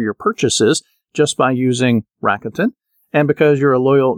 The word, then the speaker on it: Rakuten: I want to say Rakuten, but that's not the way to pronounce it. It's Rakuten.